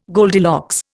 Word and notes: Goldilocks: Indian

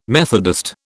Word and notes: Methodist: British English

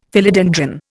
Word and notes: Philodendron: Indian

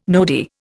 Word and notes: nodi: Indian

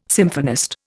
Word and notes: symphonist: Indian

